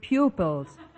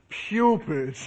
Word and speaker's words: pupils: Oh, thank you, fellow poobles. Oh, I am more fun than a little poopy dog. – All right, Mr. Schultz. And try to pronounce that word pupils. – Oh, certainly. Thank you, fellow pupils.